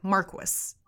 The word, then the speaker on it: Marquis: Well, it's because you're trying to make it two syllables too much. It's like Marquess. Like Marquess. Say Marquess.